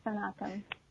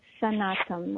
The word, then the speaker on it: Sanatam: So it's Sanatam, like sun and autumn.